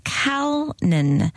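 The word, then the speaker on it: Calnin: And the name is Kalnin, Hayden Kalnin, C-A-L-N-I-N. I pronounced it Kanan because I thought that the L was an I.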